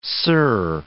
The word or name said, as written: sir